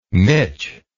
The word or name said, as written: miche